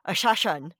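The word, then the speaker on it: Hashashin: Wine and Crime talks about it, but the way it's pronounced is like Ashashan.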